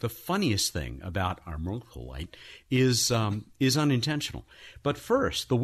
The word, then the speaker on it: armalcolite: We ask you, for whom the mineral armalcolite was named? Hey, that wasn't bad, was it? – You did very well there on pronunciation, yes.